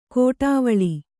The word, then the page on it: ಕೋಟಾವಳಿ: kōṭāvaḷi